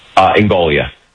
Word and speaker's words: Ingoglia: We are speaking to Blaise Ingoglia. Am I pronouncing the last name correctly, sir? – Ingoglia. – Oh, the second G is silent.